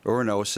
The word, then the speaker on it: Uranus: Uranos is actually the more accurate pronunciation. – Uranos, yeah. – It's Greek. Well, technically, I mean, I guess it's Roman, so Latin, but, you know.